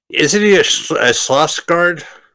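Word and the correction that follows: Skarsgård: S-T-E-L-L-A-N. Stellan. – Stellan. All right. – S-K-A-R-S-G-A-R-D.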